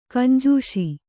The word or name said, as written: ಗುಂಜಿಸು